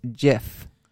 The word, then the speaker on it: GIF: And it says, the GIF creator says it's pronounced GIF.